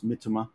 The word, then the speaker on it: Mitoma: I believe it's Mitoma because you don't emphasize the second for the last syllable in Japanese.